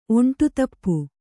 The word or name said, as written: ಒಂಟುತಪ್ಪು